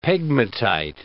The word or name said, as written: pegmatite